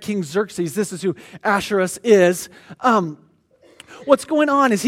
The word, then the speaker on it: Ahasuerus: Ahasuerus, which is also, his Greek name is Xerxes.